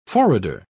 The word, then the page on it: forrader: British English